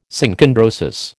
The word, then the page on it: Synchondrosis: American English